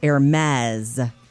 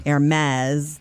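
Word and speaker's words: Hermès: Apparently, the correct answer is Hermes. Hermes. That'll take me forever to change it because I thought I was saying it right.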